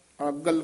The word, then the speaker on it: अगल: अगल कसके उच्चारण करना